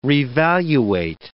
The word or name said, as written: revaluate